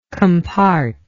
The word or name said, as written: compart